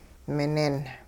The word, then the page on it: minin: hupa